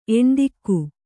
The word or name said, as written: ಎಣ್ದಿಕ್ಕು